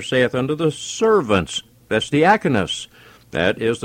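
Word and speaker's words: diakonos (διάκονος): Now, the Greek word is diakonos, and that is D-I-A-K-O-N-O-S is the Greek word for it, pronounced diakonos.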